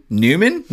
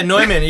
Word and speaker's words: Neumann: Well, and then it's funny because yeah, yeah, you'd think it was just Newman, but everyone says Neumann. So sure, yeah, I think Neumann.